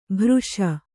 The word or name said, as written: ಭೃಶ